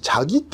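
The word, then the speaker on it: チ ャ ギ (자기): ところが、韓国ドラマではですね、あの、えー、若い恋人同士、チャギって読むんですね。チャギっていうのは事故って書くんですけども、お互い一心同体だから、自分自分って相手のことを呼ぶわけで、チャギチャギって、はいはい、呼ぶんですね。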